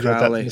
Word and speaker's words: Crowley: Crowley, like the bird. – The Ameri- yeah. the Americans say Crowley, don't yeah, they?